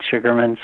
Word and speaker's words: Sugerman: My name's Fred Sugerman, spelled with an E-R. And if you Google me, you could find any number of things. Fred Sugerman, and my work is called Medicine Dance.